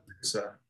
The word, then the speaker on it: Prusa: Prusa. Uh-huh. – How do you spell it? – P-R-U-S-A.